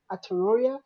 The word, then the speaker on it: Aotearoa: Aotearoa, but it's basically New Zealand.